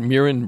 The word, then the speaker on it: Muireann: All right, out of Ireland, you just heard Muireann Bradley. It's spelled M-U-I-R-E-A-N-N.